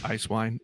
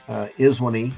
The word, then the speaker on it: ISWNE: A little bit about ISWNE, or Ice Wine, if you want to be a rebel. I appreciate it. – Or there is a third pronunciation, Iswini. – Iswini.